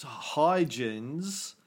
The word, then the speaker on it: Huygens: His name is pronounced Huygens. Or Huygens, but it comes from the Latin Eugenius.